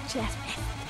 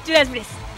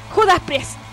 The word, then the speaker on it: Judas Priest: Porque estoy diciendo Judas Priest. Ya estoy pronunciando mal. Que... – Parece que se había arreglado el ambiente. – Judas Priest. – Bien. – Judas Priest.